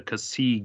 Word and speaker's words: Xi: Like it's spelled like XI Gundam, like, but I guess it's pronounced the Kasi Gundam, which is Hathaway's suit.